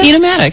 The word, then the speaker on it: Enomatic: Okay, well, your store is beautiful, and I saw something in your store that I've never seen anywhere else, and I'm probably saying it wrong. Is it Enomatic system? Enomatic system? – Enomatic. – Enomatic right.